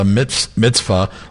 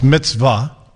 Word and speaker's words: mitzvah: Well, it's mitzvah. By the way, you Roman Catholic butchered the hell out of that. But the Pope and Caesar would be very happy with that pronunciation.